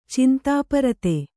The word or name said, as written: ಚಿಂತಾಪರತೆ